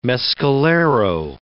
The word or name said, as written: Mescalero